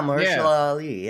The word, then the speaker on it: Mahershala Ali: But Mahershala Ali. – Oh, yeah, Mahershala Ali.